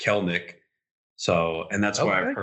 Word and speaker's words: Kelenic: I mean, it's when you look at it, when I first saw it, I said Kelenic too, because that's what it looks like. – Yeah, well, there's an E in there. – I know. – If there wasn't an E between the L and the N, then I wouldn't give a shit. Yeah. I'd be like, oh, Kelenic, no problem. The E is silent, apparently.